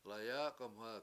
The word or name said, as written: layAAk1mha